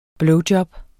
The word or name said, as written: blowjob